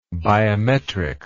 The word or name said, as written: biometrically